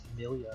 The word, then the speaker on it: Emilia: Amelia.